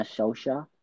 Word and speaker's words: Solskjaer: First Man United player to do it since Ole Gunnar Solskjaer, if I'm saying that right. – Yeah. – I've always struggled to say it.